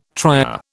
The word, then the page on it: trier: British English